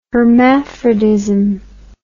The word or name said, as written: hermaphrodism